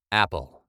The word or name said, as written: apple